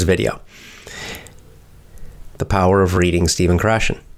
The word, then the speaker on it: Stephen Krashen: We pronounce it Stephen Krashen. But his name is spelled S-T-E-P-H-E-N. Stephen Krashen, K-R-A-S-H-E-N, if you're listening.